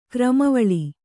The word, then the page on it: ಕರ್ಮವಲೆ: karmavale